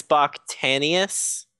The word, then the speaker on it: Bakhtanians: I can't pronounce this guy's last name. Well, you know I'm bad at pronouncing names. Yeah. Bach Tanner Tanis or what was this guy's name? That's your specialty. There you go. Aris. Aris Bakhtanians.